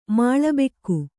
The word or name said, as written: ಮಾಳಬೆಕ್ಕು